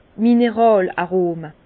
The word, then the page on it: d' Mineràl-Arome: Alsatian; Bas-Rhin; Herrlisheim